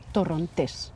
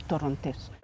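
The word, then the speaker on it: Torrontés: This wine, we say in Spanish, we call it Torontes. You emphasize, you put the stress on the last syllable, Torontes.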